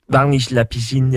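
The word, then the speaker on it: Varnish La Piscine: Jo, altså, jeg ved faktisk ikke mega meget om den her artist, men, og jeg ved heller ikke helt, om jeg udtaler hans navn rigtigt, men han hedder Varnish Lapisin. – Varnish Lapisin, ja. – ja. Det er fuldstændig korrekt.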